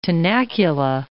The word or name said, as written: tenacula